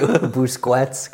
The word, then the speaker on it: Busquets: Busquets. – Because no matter how I say it, there's some issue with you. Busquets, because you can't pronounce a Q like a Q is pronounced.